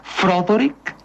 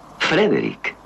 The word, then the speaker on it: Frederick: ¿Dice usted también Froderick? No, Frederick. ¿Y por qué no es Froderick Frankenstein? Porque no, es Frederick Frankenstein.